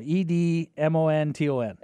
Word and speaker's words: Edmonton: Edmonton. How do you say it? – Edmonton. – Edmonton. It's like Edmonton. – Rhymes with badminton.